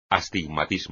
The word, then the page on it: astigmatismo: espagnol